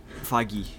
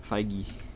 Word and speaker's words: Feige: I don't want to say his last name because I don't know how to pronounce Kevin Feige? – But Feige, yeah. Feige.